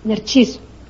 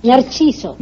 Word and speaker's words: Narciso: Narciso. – Narcissus, that's right, yeah. How do you say it? – Narciso.